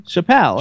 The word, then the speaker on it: Chappell: No, it's not Chaparral. – It's Chaparral. – No, come on. Don't like mess with people's names. – I'm not like, messing. No... That's how I would pronounce it. Chaparral. – But there's no R. – Where's there's no R? – There's no R in her last name. – Spell her last name. – C-H-A-P-P-E-L-L. – Chappell.